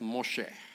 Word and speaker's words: Moshe: Moses. Or they pronounce it Moshe.